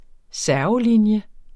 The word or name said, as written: servelinje